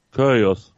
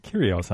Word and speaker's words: Kyrgios: Kyrgios. – Was? – Kyrgios. – Kyrgios heißt er.